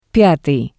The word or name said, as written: pyatii